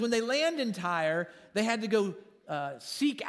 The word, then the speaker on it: Tyre: And then they land in Tyre, is how you pronounce that.